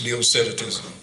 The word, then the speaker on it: Docetism: I'm not sure how you pronounce that either, but I was told it's Docetism.